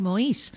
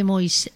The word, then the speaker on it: Moïse: Yo digo Moïse porque les oigo decir Moïse, sí. Aunque se escribe Moïse. – Moïse, sí. – Pero dicen Moïse todo el rato, no sé, sí.